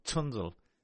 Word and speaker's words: Zundel: But how do you say his name again? – Zundel. – Right. Is that like tsunami? You know? – Yeah, something like that, yeah.